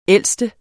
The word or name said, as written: ældste